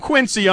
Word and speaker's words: Quincy: I don't know, but it's Quincy, Massachusetts. – I didn't know it had a Z in it. – Yeah, it does. It's pronounced that way.